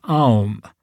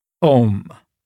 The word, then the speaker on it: Om: Since in Sanskrit, A and U coalesce in O, the sacred syllable is pronounced and often written OM, spelled O-M.